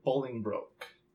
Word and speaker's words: Bolingbroke: We are talking about Henry IV, or Henry Bolingbroke. Did I say that right this time? – You did.